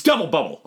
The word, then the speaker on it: Double Bubble: But the thing is, there's a lot of It's double bubble. Okay, you've been saying it wrong the entire time. You haven't given it an ounce of respect. – Sorry. Double bubble.